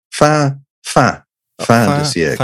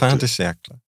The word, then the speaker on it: Fin de siècle: I mean, even after, and I'm going to get the pronunciation of this wrong, but Fin de siècle? – Very, very good. Fin de siècle. – Fin de siècle.